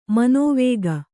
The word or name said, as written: ಮನವುಕ್ಕು